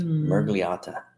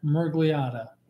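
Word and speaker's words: Miragliotta: So how do you say it? Dan Mirgliotta. – Mirgliotta. – Yeah. – That sounded better.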